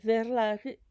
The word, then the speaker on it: Verlag: Es un término técnico económico alemán y ustedes lo pueden pronunciar Verlag y se escribe V-E-R-L-A-G de gato.